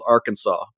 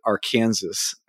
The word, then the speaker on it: Arkansas: It's spelled Arkansas, but you pronounce it Arkansas.